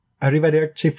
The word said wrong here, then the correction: Arrivederci: You just have to roll your R's more. – Arrivederci, Flavia. – Yes. But less like a Mario brother. – Arrivederci, Flavia. – Yes. Much better.